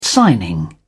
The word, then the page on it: signing: British English